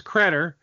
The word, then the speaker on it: Credder: But my, the last thing I want to ask you is, you know, I know the name's Credder, which rhymes with cheddar.